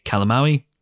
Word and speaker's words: Calamawy: played by May Calamawy, which, as you'll soon hear, is the correct pronunciation of her surname; and of course, Marc Spector himself.